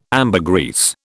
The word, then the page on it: ambergris: British English